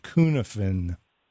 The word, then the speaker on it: Kunafin: Cunafin, and it's spelled K-U-N-A-F-I-N, Cunafin, and they sell fly parasites.